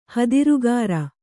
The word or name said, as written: ಹದಿರುಗಾರ